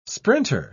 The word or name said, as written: sprinter